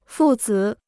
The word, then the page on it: 父子: fù zǐ